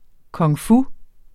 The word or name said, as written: kung-fu